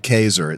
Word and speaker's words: Caeser: Near where my grandparents lived and my great great grandparents lived, there's a town called Caesar. It's spelled Caeser. – Like Caesar. – Yeah. It's Caesar.